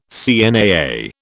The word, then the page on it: CNAA: British English